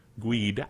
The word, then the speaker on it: GUID: G-U-I-D, group user ID. GUID. I love GUID. It's fun to pronounce it that way.